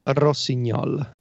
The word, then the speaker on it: Rossignol: Do you want the really Italian pronunciation? – Yes. – Yeah, that's why I looked at you. It looked Italian to me. – Rossignol. – There you go.